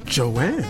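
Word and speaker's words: Joann: Is it Joan or Joanne? – Joanne. – It is Joanne.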